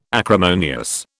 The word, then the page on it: acrimonious: British English